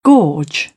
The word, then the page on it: gorge: British English; ɔː